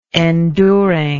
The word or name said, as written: enduringness